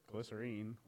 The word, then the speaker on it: Glycerine: Glycerine, that's how he pronounces it. – Say it right.